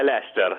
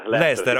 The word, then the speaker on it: Leicester: Poi, facciamo una cosa, ci dica subito come si pronuncia, che qua lo diciamo tutti in un modo diverso. Gira anche un video su come lo pronunciano i romani. – Ah sì, no, è Leicester.